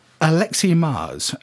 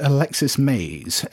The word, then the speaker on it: Alexi Mars: This is a listener by the name of Alexi Mars. So I think I was probably responsible here. I'd assumed that it was pronounced Alexis Mays, which is nothing like how it's pronounced.